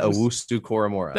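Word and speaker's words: Owusu-Koramoah: Jeremiah Owusu Koramora.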